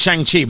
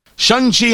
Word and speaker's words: Shang-Chi: See, I'm pronouncing it Shang-Chi, but that's not it. – Shang-Chi and the Legend of the Ten Rings.